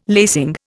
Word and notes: lacing: Indian

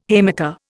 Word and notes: haymaker: Indian

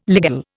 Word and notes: ligule: Indian

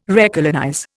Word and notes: recolonize: Indian

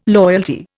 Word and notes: loyalty: Indian

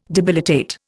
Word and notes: debilitate: Indian

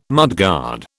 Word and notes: mudguard: British English

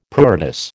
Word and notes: pruritus: American English

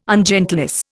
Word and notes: ungentleness: Indian

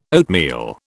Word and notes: oatmeal: British English

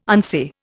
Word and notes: unsay: Indian

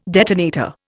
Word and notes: detonator: Indian

